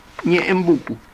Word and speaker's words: Ñeembucú: Y es seguro que si los que dieron nombre a este afluente quisieron manifestar derrame largo, Ñeje, Pucú, y solucionaron la dificultad con el más fácil y más simple, Ñeembucú. Y así seguimos pronunciando todos los pilarenses,